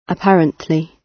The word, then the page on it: apparently: British English